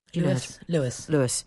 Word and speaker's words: Luas: Lewis. – Yes, Lewis is Irish for speed and pronounced Lewis, she says, in all fairness.